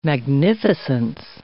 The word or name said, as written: magnificence